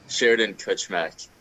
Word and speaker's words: Sheridan-Kuchmek: Sheridan-Kuchmek.